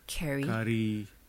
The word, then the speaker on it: Kari: It's spelled like how we spell Kari. – Oh, okay. – Kari. Like Carly, but only with a K and no L. – K-A-R-I?